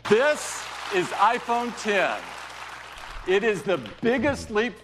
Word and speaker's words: iPhone X: It's pronounced iPhone X. – Really? – Yeah, okay. They clarified it yesterday. It's not X. It's iPhone X.